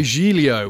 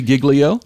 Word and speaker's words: Giglio: You are wanting to refer, I'm going to say Giglio, I think would be a more accurate pronunciation, but his actual name is... – It's accurate in your mind, and I understand you're European, but his name is Louis Giglio. – Giglio, okay.